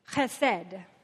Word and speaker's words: chesed: Chesed. You like that? Chesed means loving kindness.